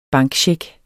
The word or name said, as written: bankcheck